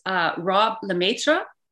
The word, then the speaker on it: Rob LeMaitre: His name is Rob LeMaitre.